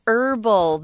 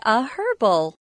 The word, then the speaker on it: herbal: But in America or the United States, for the most part, most people say herbal. They leave that H sound out. But then you'd have to change the definite object to an herbal. But if you're pronouncing it in the British fashion, you'll want to say a herbal.